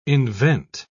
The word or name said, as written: invent